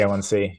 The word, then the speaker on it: HbA1c: There's the vitamin D test, and I always say this wrong, the HAB1. – Blood sugar, the one, fatty red cells. I always mix up the letters. – HbA1c, hemoglobin A1c.